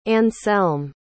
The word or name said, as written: anselme